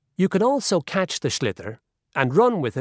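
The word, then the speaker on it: sliotar: This is what it looks like, and basically you have to get this ball, called a slither, that's S-L-I-O-T-A-R, so it's not spelt the way it's pronounced.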